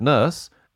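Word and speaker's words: Nourse: I pronounce it Nurse.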